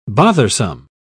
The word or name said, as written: bothersome